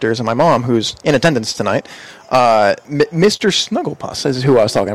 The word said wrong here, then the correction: Snuffleupagus: Snuffleupagus, yeah.